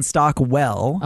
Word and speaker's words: Stockwell: That's the Dean Stockton tie in there from Battlestar. – It's actually, I'm giving it to you, but you are pronouncing it incorrect. It's Dean Stockwell.